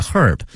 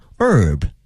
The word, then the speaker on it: herb: 英国人会把这个 H 这个 herb 这个 h 这个音呢给念出来 herb，但是美国人啊会把这个第一个字母 H 给它吞进去，直接念成 herb 啊。所以呢大家听不同国家的人念这个词的时候呢要小心了。